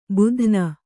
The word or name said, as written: ಭೇದನ